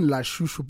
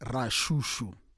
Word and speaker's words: Lashushu: It's written Lashushu, but pronounced Rashushu. – Why is that? Because of in Zulu, you don't have the R.